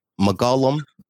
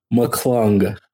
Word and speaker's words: McClung: McGullum. – Man, I love when you pronounce names. It's really my favorite thing to do is listen to you pronounce names. McClung.